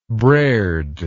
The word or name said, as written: braird